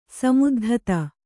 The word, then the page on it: ಸಮುದ್ಧತ: samuddhata